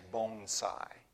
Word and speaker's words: bonsai: Actually, you said it the American way, but the Japanese pronunciation is bonsai. Right? So just think of it, oh, my bones are sighing this morning.